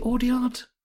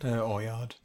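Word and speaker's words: Audiard: And if we're really honest, is it Audyard? Audyard or Oyard? How do you say it? Audyard or Oyard? – Oyard. That's Oyard. Yeah, I mean, we know Oyard can direct a film.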